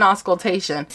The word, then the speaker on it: auscultation: Can you pronounce it for me? – It's intermittent auscultation.